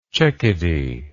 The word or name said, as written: checkedy